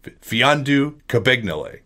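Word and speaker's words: Mfiondu Kabengele: And the M is silent. So Fiondu Kabengele.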